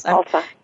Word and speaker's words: Khalsa: Khalsa.